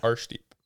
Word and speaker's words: Arshdeep: Ashdeep Baines. – Yeah, Arshdeep.